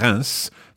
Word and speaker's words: Reims: Now, I think quand, C-A-E-N, is probably, along with Reims, that's R-E-I-M-S, probably one of the most badly pronounced places in the whole of France, at least.